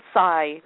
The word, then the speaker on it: psi: And actually, it was really frustrating because the word psi, the P is silent, and psi is really just sort of an umbrella term for paranormal, psychic phenomenon, mind over matter.